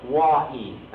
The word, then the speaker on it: Oahe: It's spelled O-A-H-E and pronounced from the Sioux, Wa'ee, and I don't know what it means.